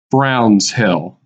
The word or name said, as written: Browns Hill